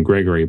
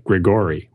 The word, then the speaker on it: Gregori: Uh, Here's an email from Jason Gregori, who was nice to point out that his name, which is spelled G R E G O R I, is pronounced like the first name Gregory, but not like Grigori, which is exactly how I was going to pronounce it.